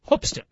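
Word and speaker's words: Hoopeston: If you're into basketball, it's Hoopston. The actual pronunciation of the town is Hoopston.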